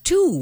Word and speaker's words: Twoo: They launched Tuo, which is spelled T-W-O-O.